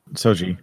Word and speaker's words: Soji: Isa Broyns as Daj and Soj. Am I saying this right? – Soji. – Daj and Soji.